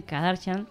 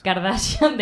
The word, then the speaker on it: Kardashian: Y le lleva una de las Kardashian. No sé si lo digo bien, Kardashian. – Kardashian es. – Kardashian de no Cardo, sé.